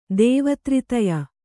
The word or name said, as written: ದೇವತ್ರಿತಯ